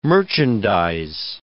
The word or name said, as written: merchandise